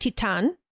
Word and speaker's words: Titane: I've been calling it Titane, but it is a French film, so it is actually pronounced Titane.